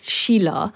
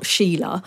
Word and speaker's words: Sheela: Well, Sheela is a perfectly ordinary, acceptable name over there. It's pronounced Sheela. So it's not Sheela, and it means it's quite a common Bengali name.